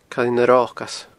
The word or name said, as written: coillenanrocas